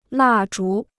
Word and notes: 蜡烛: là zhú

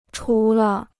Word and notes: 除了: chú le

